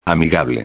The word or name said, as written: amigable